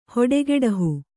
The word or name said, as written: ಹೊಡಗೆಡಹು